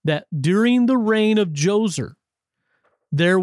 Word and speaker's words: Djoser: This is a man by the name, or a pharaoh by the name of Djoser, and it begins with a D. It's funny, silent D, J-O-S-E-R, sometimes called Djoser.